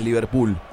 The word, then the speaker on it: Liverpool: ¿Qué hace el Liverpool? – Ah. – Mira vos. – Bien pronunciado, Liverpool. – Liverpool. – Liverpool. – Los uruguayos dicen Liverpool. Uruguay y Liverpool.